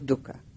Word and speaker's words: dukkha: The first one is the noble truth of dukkha. Dukkha is spelled D-U-K-K-H-A.